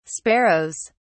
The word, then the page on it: sparrows: British English